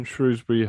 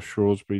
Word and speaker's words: Shrewsbury: Don't Shrewsbury have, or Shrewsbury, depending on how you want to pronounce it, have a bit of a relationship? – Yeah.